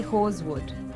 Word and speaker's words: Horswood: We say Horswood. – Horswood. – Yeah, like the S is not Horswood.